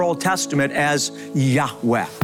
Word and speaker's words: Yahweh: feel is pronounced a breathy Yahweh.